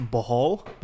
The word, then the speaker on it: Bohol: It's Bohol. – Bohol, Philippines.